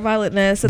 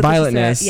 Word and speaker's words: Violetness: Check out Violetness. I don't know if I'm saying that right. – You are Violetness. – Violetness.